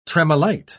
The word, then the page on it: tremolite: British English